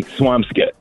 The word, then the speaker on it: Swampscott: But it's actually Swampscott. – Swampscott. Let them know. – Yeah. – Who is that in the background? Your wife? – That's my wife, Crystal. – Crystal knows. Swampscott.